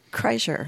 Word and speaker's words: Kreischer: Bert Kreischer? – Kreischer.